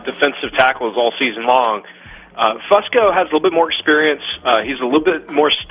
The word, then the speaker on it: Fusco: Fusco, yes.